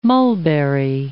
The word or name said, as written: mulberry